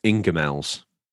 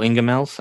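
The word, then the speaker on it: Ingoldmells: Now, that's pronounced Ingemells. – Oh, Ingemells. That's an English thing, I guess.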